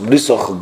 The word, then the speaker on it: Brisoch: But the Rebbe used to say it, when the Rebbe used to say Selichos, they say, he pronounced it with the vowel, Leman Brisoch, and Leman Amisoch. It's already in the Selichos over there, and so in all the Amirisoch, Brisoch, Godloch, Tifartoch, all with the komats over there, not Techo, but Toch.